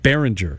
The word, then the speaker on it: Berenger: Because I know that Berenger, am I saying that right? – Tom Berenger, I think you're right, Berenger.